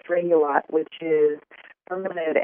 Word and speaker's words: Regulat: Yes, it's called Rex Regulat, and it's spelled R-E-C-H-T-S. It's from Germany, so that's why it's got kind of a strange name. And then the Latin part of it is R-E-G-U-L-A-T, Regulat.